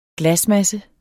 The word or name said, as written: glasmasse